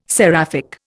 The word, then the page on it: seraphic: Indian